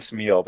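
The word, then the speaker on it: Smyl: It's Stan Smeal,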